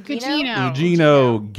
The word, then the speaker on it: Gugino: Gugino.